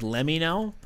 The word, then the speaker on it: LEMMINO: It's spelled LEMMINO, like Let Me Know. – Gotcha. – But it's supposed to be, you know, Let Me Know is what it is.